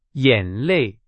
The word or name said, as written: 眼泪